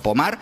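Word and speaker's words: Pommard: Se escribe Pomard, como si fuera en castellano, pero Pomard, pues allí en Francia, hablamos de unas imágenes.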